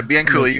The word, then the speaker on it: Bianculli: Did I pronounce that right? Because I made that whole rhyme thing. Okay. – Yeah, Bianculli. You got it right.